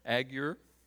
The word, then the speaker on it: Agur: The words of Agur, strange name. Hope I'm pronouncing that correctly. Some of you Hebrew scholars, is that okay, Adam? Agur, okay.